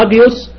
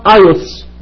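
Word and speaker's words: هاجيوس (ἅγιος): لما أجي أقول لك على صفة زي كلمة هاجيوس يعني قدوس أو قديس، أنا بنطقها هاجيوس لأن في تنفس هائي فوق الألفا وحرف الجاما وحرف اليوتا أوميكرون سيجما. هتلاقي واحد تاني ينطقها ايوس باليوناني الحديث، صح يا دكتور؟ صح، لان اليوناني الحديث ما فيهوش تنفس هائي، فمش هيقول ها، هيقول ا، والجاما يوتا في اليوناني الحديث تتنطق اي، تبقى ايوس ايوس عادي.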